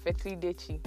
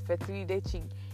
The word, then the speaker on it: fetri dechi: So, we, the Ewes, call it fetri dechi. If you can't pronounce it, that's fine. But it's fetri dechi in Ewe.